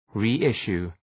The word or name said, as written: reissue